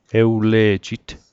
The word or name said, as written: ewle'jit